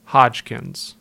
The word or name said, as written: Hodgkins